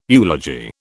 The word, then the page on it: eulogy: British English